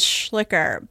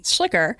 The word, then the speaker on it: Schlicher: It's often pronounced as Schlicher, but it's a funny thing because it's actually misspelled. So when my ancestor came over, and I had to study this genealogically to understand what the problem is, because when I was going to Germany to work for a German company, people would look at it and be like, you don't say your name right. So we would say Schlicher, but in German, it's actually more like Schlicher. There should be a K there.